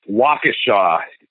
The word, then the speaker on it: Waukesha: Waukesha is the correct pronunciation.